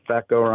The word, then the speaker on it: FECO: Like, no, FECO, full extract with an E, so, with an F. So I just always tell people, just like it rhymes with terror, FECO rhymes with echo.